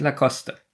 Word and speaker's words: Lacoste: Na, Lacoste. – Da ich nicht weiß, wie das ausgesprochen wird. – Bin ich im Vorteil. – Hast du verloren. – Ja. – Das heißt nämlich Lacoste.